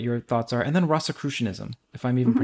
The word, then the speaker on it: Rosicrucianism: Rosicrucianism. So Rosicrucianism is a mystical element tradition within Christianity that it's called the Holy Cross, the Rosy Cross. Okay. And Rosicrucianism.